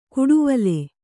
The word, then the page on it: ಗೂಡುವಲೆ: gūḍuvale